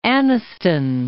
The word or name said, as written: Anniston